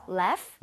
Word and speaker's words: laugh: Laugh. – Laugh? Okay. 자, 그러면 여기 써주세요. – Laugh at... – 스펠링을 제대로 만들어주세요. – Laugh 같은데요? – GH는 F와 비슷하게 발음이 됩니다.